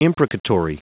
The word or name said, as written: imprecatory